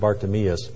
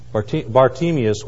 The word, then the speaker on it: Bartimaeus: Betsy and I were talking about so many different ways to pronounce this, but I think it is actually pronounced Bartimaeus. He was there.